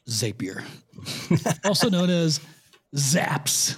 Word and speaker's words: Zapier: So first I would like to politely correct you on the pronunciation. It's it's Zapier, because Zapier makes me happier.